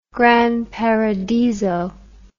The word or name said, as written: granparadiso